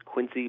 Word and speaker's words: Quincy: I am doing great in the city of Presidents, Quincy, Massachusetts. I'm not a local, but the locals will remind me to pronounce it with the Z, not the C-Y.